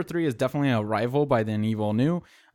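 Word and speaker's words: Villeneuve: I hate the fact that you said his name properly, because I call him Villanueva, and now I can't. – Gross. That's not how you pronounce it. He's French. Or Canadian? – He's Canadian. – Yeah, he's Canadian French.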